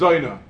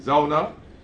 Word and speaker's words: zona: So the Torah itself used the word zona as zoina. The Balkaris don't speak with an oi. They pronounce it properly. Zona. Even though it's really a Yiddish term, zoina.